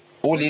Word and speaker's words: أولي: الواو دي غير منطوقة. كتير من الناس بيقول ايه؟ قولي. هي قولي. بص الفرق قد ايه؟ مش كبير، بس ابقى اثبت حرف في القران محذوف في النطق، هو ثابت في الرسم، لكنه مش ايه؟ مش منطوق.